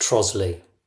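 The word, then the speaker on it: Trottiscliffe: It's spelt Trottiscliff, but is pronounced Trosley.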